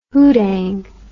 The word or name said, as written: houdang